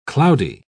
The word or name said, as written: cloudy